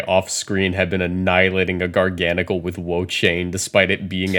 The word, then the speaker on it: Garganacl: It's Garganacl, because it's gargantuan plus the salt chemical formula.